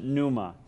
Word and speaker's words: pneuma: Spirit, that Greek word there, pneuma, we pronounce it pneuma.